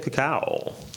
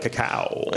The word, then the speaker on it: cacao: I had to say it like cacao. – Yeah. – I like saying it like that. – Yeah. I'm glad you said it right. Some people do not say it right. – Cacao. – Yeah. I'm like, can you read? So no, like when you're just like, can I have a taco? Like, that's not how you say that.